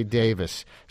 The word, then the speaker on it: Davies: Your last name is D-A-V-I-E-S, which I would pronounce Davies, like Ray Davies of the Kinks. But you say Davis. So you have to tell me, was I saying Ray Davies' name wrong all these years? – Yeah.